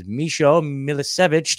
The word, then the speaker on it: Misho Milicevic: So this is Misho Milicevic.